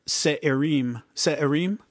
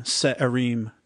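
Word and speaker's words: Se'irim: I can't remember how he said it, but it's spelled S-E-apostrophe-I-R-I-M, Se'erim.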